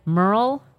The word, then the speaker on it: Merle: Now, I do want to ask you about the I don't know if I'm going to say this correctly, Campbell Flanagan Merle House? – Yes. Yeah, you said it correctly.